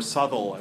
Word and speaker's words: Southwell: So for Southwell, or Southwell as he's actually pronounced, the inspiration was an image of a burning child, an amazing image.